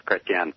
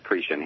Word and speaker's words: Chretien: Well, my grandparents were from Quebec, Canada, back in the day in the 30s. So if you go to Quebec, everybody says Chrétien. – See. – But here it gets, you know, like all immigrants, your name gets changed, so it's pronounced Cretien here. – And it's spelled C-H-R-E-T-I-E-N.